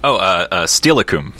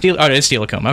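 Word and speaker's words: Steilacoom: Steelicum. – It's Steelicum. Okay. Steelicum.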